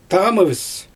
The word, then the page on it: tamɯvɯs: Xyzyl